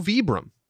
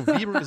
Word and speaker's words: Vibram: Well, we got an email, and it's also Vibram because it's an Italian company, which, like, duh, I knew this. And so Vibram is a totally acceptable pronunciation. And Vibram is also sort of the commonly used American pronunciation.